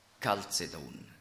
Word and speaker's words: Chalcedon: Also das CH wird eigentlich im Griechischen wie ein feines C ausgesprochen, nicht ch, wie die Schweizer das CH aussprechen, ja, sondern Chalcedon. Und da steckt das Wort Kupfer drin.